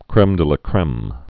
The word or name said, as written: creme de la creme